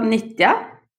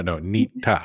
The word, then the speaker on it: Nitja: It's called Nitja. – Nitja? No, Nitja. – No, it's not the ch. It's Nitja. So it's like ninja, but then you would exchange the N for a T.